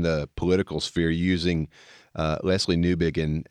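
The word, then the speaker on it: Newbigin: His name is Leslie Newbegin, just like you mentioned.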